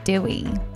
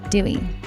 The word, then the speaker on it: DDWY: And right before that, we were listening to DDWY. I think it's pronounced Dewey. Dewey.